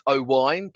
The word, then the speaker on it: Owain: So you have Owain, which actually should be pronounced Owain because it's Welsh. You have Henri, H-E-N-R-I.